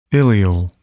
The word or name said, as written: ileal